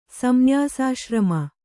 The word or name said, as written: ಸಂನ್ಯಾಸಾಶ್ರಮ